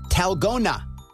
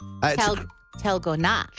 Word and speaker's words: Dalgona: It's spelled D-A-L-G-O-N-A, but it's pronounced Talgona. – Talgona.